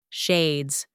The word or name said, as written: shades